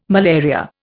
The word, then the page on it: malaria: Indian